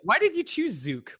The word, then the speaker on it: Zook: And so now I'm Jason Zook or Zook. It's totally cool if the Canadians say Zook because that is phonetically how it probably should be pronounced.